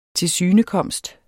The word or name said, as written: tilsynekomst